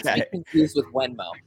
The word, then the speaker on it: Venmo: But say that word you're saying, but spell it. – The Venmo, V-E-N-M-O.